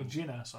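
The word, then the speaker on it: Jyn Erso: What about Jen Erso? – Jen, yeah, and yeah. Captain. – Or Jin Erso, I said it wrong.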